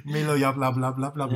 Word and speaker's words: Milo Yiannopoulos: I probably butchered that. – Yeah, I don't know. – Milo Yiannopoulos. – Yeah, well done. That's really good.